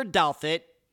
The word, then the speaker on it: Douthat: Christopher Douthat, and yep, that's how you pronounce his last name, had a rough upbringing.